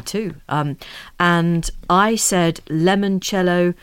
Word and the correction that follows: limoncello: and Anna, who's Italian, says, it's limoncello, and please pronounce all the consonants in mascarpone.